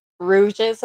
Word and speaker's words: Rouges: Rouges, am I saying it right? – Yes, I am.